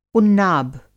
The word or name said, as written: उन्नाब